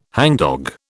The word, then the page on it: hangdog: British English